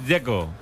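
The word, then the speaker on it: Džeko: A mí el que me parece difícil es el de Checo, porque de Z, ¿cómo se pronuncia? – Dzeko. Bruno dice que es Dzeko. – Como tirando a J, Dzeko o algo así.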